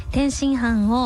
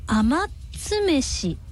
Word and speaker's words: 天津飯: その昔、天津飯を甘つめしって読んで、そういうことね、甘つめしって読んで大笑いされたことがあります。